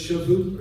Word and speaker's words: Shavuot: And so since then, I'm going to probably butcher the pronunciation, but it's Shavuot or Shavuot. It's S-H-A-V-O-U-T. You say it how you want to.